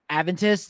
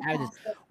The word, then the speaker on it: Adventist: a Seventh Day um Adventist. Is that the correct pronunciation? Yes, okay.